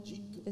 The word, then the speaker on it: Jeet: Jeet.